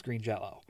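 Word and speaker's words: Green Jellÿ: So that's why it's spelt green jelly, but you got the little dots that make it uh, pronounced green jello.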